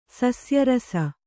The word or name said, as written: ಸಸ್ಯರಸ